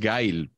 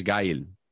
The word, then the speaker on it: Gail: Que em, Luis Miguel, yo creo que es Gail, porque no es, no, o sea, no es, no lleva tilde la I. – entiendo, ¿eh? que es un diptongo y que es Gail.